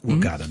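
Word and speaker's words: Hurghada: Spricht man es Urgada? Ich kann das nie richtig. – Urgada, da waren Sie auch.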